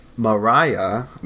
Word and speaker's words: Maria: Mariah, that's how you pronounce it, right? – Yeah, I would say Maria, but they pronounce it Mariah.